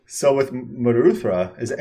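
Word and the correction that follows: Muldrotha: You can pronounce it any way you want, but I think the real terminology is Moldrotha. – Moldrotha.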